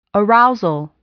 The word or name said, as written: arousal